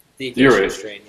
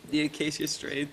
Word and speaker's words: The Acacia Strain: The Arcadia. I thought I heard someone scream in my house. Arcadia. Did I pronounce that wrong? – The Acacia Strain. Yes. You're good. – The Acacia Strain.